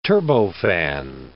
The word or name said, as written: turbofan